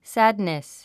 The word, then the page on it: sadness: sǽdnis; サドネス